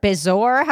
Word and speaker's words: bezoar: It's called the B-E-Z-O-A-R. Bezoar? How would you say that? – Bezoar. – Bezoar. It's called a Bezoar.